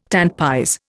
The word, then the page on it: tant pis: Indian